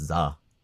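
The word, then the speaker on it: Z-A: earlier this year, we got an announcement trailer for Pokemon Legends Zeta A, which is the next entry in the Pokemon Legends series. – I think it's pronounced Za.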